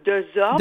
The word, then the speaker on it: Desorme: C'est comme ça qu'il faut le prononcer? – Oui, Dan Desorme. – Desorme. – Oui, Desorme.